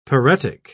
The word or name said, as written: paretic